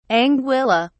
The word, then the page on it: anguilla: British English